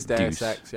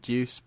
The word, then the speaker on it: Deus Ex: It is Deus Ex. Yeah, I used to pronounce it Deuce, but it's Deus.